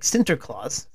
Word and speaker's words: Sinterklaas: So if you move to the Netherlands, there's Santa Claus, or I think Sinterklaas is how you say it,